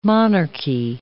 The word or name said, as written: monarchy